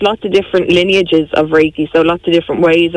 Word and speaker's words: Reiki: So it's pronounced Reiki.